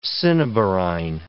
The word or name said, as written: cinnabarine